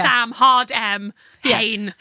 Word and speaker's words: Samhain: It's called Samhain. And then in brackets, it says like how to pronounce it. Yeah. Sam, hard M, Hain. – Hain. – Rhymes with rain.